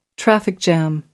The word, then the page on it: traffic jam: American English